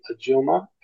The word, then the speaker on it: Ajuma: You can if you go to the name Ajuma, and it's spelled A J U M A.